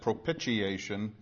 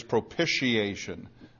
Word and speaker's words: propitiation: Generally, you'll hear it said propitiation, like pitch, like pitching a ball, and that's really not right, and Dave Greenwood will tell you this because he's big on pronunciation. It is propitiation, like pish instead of pitch.